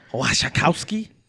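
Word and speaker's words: Wasikowska: And Mia Wasikowski. – Yeah, you said it right, dude.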